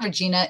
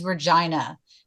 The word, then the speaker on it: Regina: because I have since learned that it is not Regina, it is Regina. And I imagine that that happens a lot. – It happens all the time. And I noticed that you got it correctly this time. But that's, you know, we help people through that little problem by saying that, you know, we live in the city that rhymes with fun.